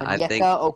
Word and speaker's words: Onyeka: Okinya Okongwu. – That is a great pick because that's also my pick. – Yep. – I think Onyeka sorry for my pronunciation. Yeah, Onyeka Okongwu.